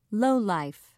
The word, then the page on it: lowlife: British English